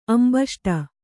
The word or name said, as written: ಅಂಬಷ್ಠ